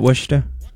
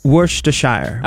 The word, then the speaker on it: Worcestershire: Was that Worcester? – Worcester sauce? – Worcestershire. – Okay. – Worcestershire sauce.